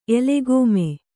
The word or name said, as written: ಎಲೆಗೋಮೆ